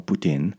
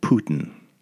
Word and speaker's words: poutine: And what happened was she went on to make this video making poutine or poutine, and she called it Putin through the whole video. And man, and then she credited me too. She shouted out like, thanks for telling me how to pronounce Putin.